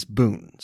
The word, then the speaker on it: bún: The Vietnamese word for what we know as vermicelli is bún, spelled B-U-N. While I've enjoyed it for years, I'm embarrassed to say I was only recently corrected for pronouncing it bun.